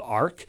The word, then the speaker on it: ARQ: So we developed a commercial solution called ARC, short for architecture, but spelled with a Q, just like BlueStack, so you can remember it, A-R-Q.